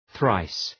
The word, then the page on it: thrice: ɵraıs